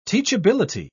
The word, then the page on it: teachability: British English